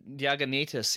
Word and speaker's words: Diognetus: Diognetus, yeah.